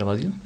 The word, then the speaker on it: Badiou: Este autor francés, que no sé cómo se pronuncia, Alain Badiou, ¿será Badiou? Sí.